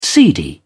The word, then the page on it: seedy: British English